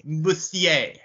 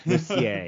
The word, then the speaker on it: Mercier: It's Mercier. Mercier. Mercier. It's like Cormier.